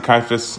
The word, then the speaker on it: Caiaphas: And so I'm going to pronounce it Caiaphas because that's what that Mel Gibson movie pronounces it. And I really like that movie. And so I don't know. But we're going to go with Caiaphas.